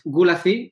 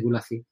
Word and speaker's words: Gulacy: Entonces, em, bueno, pues Gulazi, que se escribe así, Gulazi, G-U-L-A-C-Y,